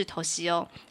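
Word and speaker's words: Toshio: 然后他又说了，哎，既然是敏夫，就是其实这个敏夫的日文发音就是头西欧。他说，如果是头西欧。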